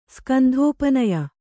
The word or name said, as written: ಸ್ಕಂಧೋಪನಯ